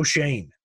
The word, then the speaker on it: Schoen: I watched a feature on one of Landry's guys last night, the Bills assistant GM, Joe Shane, and which it's weird. He kind of bothers me that that's how they pronounce his last name. S C H O E N is not Shane.